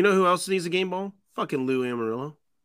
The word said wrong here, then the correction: Anarumo: Yes. – Amarillo? Yeah. Who the fuck I can't ever pronounce it. – Armadillo? Anarumo. – Anarumo. Lou Anarumo.